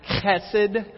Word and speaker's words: chesed: In Hebrew, it has a harsher sound. It's chesed. I still remember Dr. Allen teaching us about it. He says, you know you said it right if the person in front of you has to wipe off their neck.